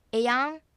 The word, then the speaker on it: えやん: えやんはえやんではなく、えとやんがひとまとまりになるようにえを短めに、やんを強く長めに発音するのがコツです。えやんですね。